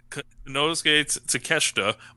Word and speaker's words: Konosuke Takeshita: Konosuke Takeshita, but I had to listen to Kenny Omega say it first.